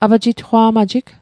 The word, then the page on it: apajitqwa'majig: Mi'gmaq